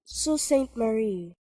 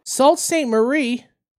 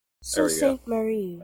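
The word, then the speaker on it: Sault Ste. Marie: What is it? Salt? – So Saint Marie. – Hello? Hello? – Salt Saint Marie? – So Saint Marie. – That should be good. – I'm not doing any of that to Mary. Anyway. – That's how you say it.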